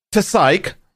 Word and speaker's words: TSAIK: The overclocker Psyche, who I have most likely mispronounced, but it's spelled T S A I K, has managed a 5,923.62 megahertz overclock with the assistance of some LN2.